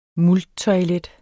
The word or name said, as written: multtoilet